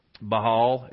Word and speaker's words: Baal: I know we use the word Baal, Baal is kind of how it's really actually pronounced.